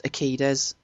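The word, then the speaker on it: Urquidez: Was it Benny Urique? How do you say it? Urique's? Benny the Jet. I'm sure I've heard it pronounced Akides, but I could be. – Akides, okay, right.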